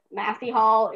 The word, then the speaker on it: Massey Hall: There are various variations of saying it, but Massey Hall is actually Massey Hall.